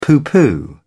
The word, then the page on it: pooh pooh: British English